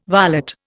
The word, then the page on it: varlet: Indian